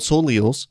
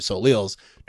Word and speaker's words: Soleil: Which, by the way, I found out is not what they are pronounced. – How do you say it? Soleil? – Yeah, they are pronounced Soleil.